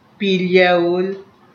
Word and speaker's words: Pilyaul: And the name of my section in the Amlet comprised of three syllable word, Pilyaul, which conveys of two languages, Ponabean and Cushaian.